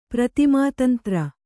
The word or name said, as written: ಪ್ರತಿಮಾತಂತ್ರ